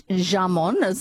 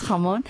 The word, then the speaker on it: jamón: And, it's not jamón. It's spelled jamón with a J, but it's jamón.